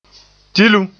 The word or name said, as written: tilum